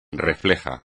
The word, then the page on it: reflejo: espagnol